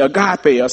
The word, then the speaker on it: agape: It's this third, it's this fourth love, and you all, many of you have heard of it, agape, A-G-A-P-E, agape, or sometimes it can be pronounced agapeo, A-G-A-P-A-O, either one of those, agape or agapeo.